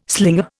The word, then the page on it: slinger: Indian